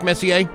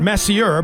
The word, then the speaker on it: Messier: One of the favorites, Messier. – Yeah. After Mark Messier? – Yeah. Not Messier, but it's spelled that way.